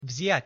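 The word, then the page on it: vziat: Slovak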